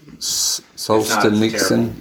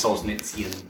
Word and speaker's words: Solzhenitsyn: So the Solzhenitsyn. – Yes. – Solzhenitsyn.